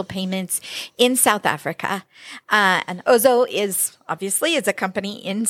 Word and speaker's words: Ozow: And so I said that right. – Ozow? – Oh, Ozow. I knew I would get something wrong. The easy one I got wrong. All right.